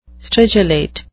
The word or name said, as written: stridulate